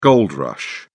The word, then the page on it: gold rush: British English